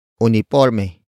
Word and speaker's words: uniporme: And that object is uniforme. Uniforme. Uniforme. Uniforme is spelled as U-N-I-P-O-R-M-E.